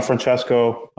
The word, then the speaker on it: Francesco: So I'm Francesco, CEO of Kadena Eco, the ecosystem growth company for Kadena.